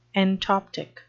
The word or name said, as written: entoptic